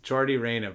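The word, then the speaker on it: Yordy Reyna: Well, you've pronounced his name wrong again. But, it was a good joke overshadowed by your inability to pronounce the poor man's name right. And you best believe in that courtroom they get it right. – You think so? – They say, Jordy, mate, can you please, Jordy Reyna, please rise so we can read the charges against you.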